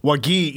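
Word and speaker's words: Wague: Wagi, yeah, I believe so. It actually looks like Wagyu, or you know that meat. But yeah. I looked it up earlier, and it's Wagi.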